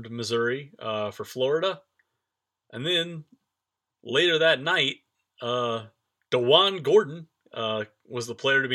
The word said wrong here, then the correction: Deshaun: Now, you're already pronouncing it wrong. – What is Deshaun. It? Deshaun. I'm sorry, Deshaun.